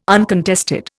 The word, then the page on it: uncontested: Indian